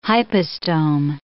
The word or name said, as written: hypostome